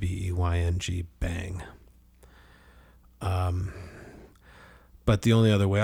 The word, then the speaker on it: Beyng: And just a reminder that when we say bang, we're pronouncing the word B-E-Y-N-G, which is an alternative spelling of B-E-I-N-G.